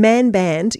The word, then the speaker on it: Mannbannd: And Mannbannd, you've got a couple of double N's in there, but I'm still saying it correctly, am I? – That's correct, yes, you can overstress the N if you like.